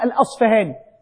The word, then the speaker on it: الأصفهاني: كلمة الأصبهاني أحيانا نقف على بعض الناس يقول أبو نعيم الأصبهاني وبعضهم يقول أبو نعيم الأصفهاني، هذا صح وهذا صح. المشارقة لهم نطق يقولون الأصفهاني والمغاربة يقولون الأصبهاني، واضح؟